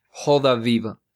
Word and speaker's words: Roda Viva: Joda viva. es que la R se pronuncia como una J.